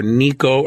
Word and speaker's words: Necho: But one guy to remember is spelled Net, like Necho, N-E-C-H-O, but it's pronounced either Nico or Necho. I'll probably use Necho.